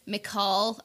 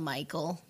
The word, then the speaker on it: Mical: I think his name is pronounced uh, Mical. I call him Michael, but mm-hmm.